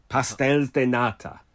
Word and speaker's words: Pastéis de nata: Pastéis de nata. – Pastel de nata.